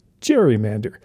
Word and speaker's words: gerrymander: They named the newly mythical beast after the governor, Elbridge Gerry, spelled G-E-R-R-Y. The result? The gerrymander, which we now pronounce gerrymander.